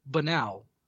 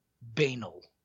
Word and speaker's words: banal: This guy pronounced the word banal. Banal. It's not banal. The way you pronounce the word is banal. But he said banal.